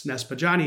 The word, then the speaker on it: Niespodziany: So when I was in middle school, my friend's dad couldn't pronounce Niespodziany.